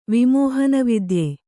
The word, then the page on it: ವಿಮೋಹನವಿದ್ಯ: kannada